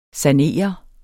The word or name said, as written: sanere